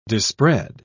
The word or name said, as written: dispread